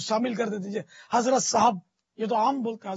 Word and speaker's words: صاحب: حضرت صاحب, اصل تو تلفظ صاحب ہے, صاحب صاحب نہیں صاحب, تو حضرت صاحب کہہ کر بھی تو وہ تعظیم بڑھا دی نا اس کی.